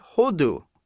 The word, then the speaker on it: hodu: Now, the Hebrew word to give thanks is pronounced hodu. Ironically, hodu is the same word for turkey.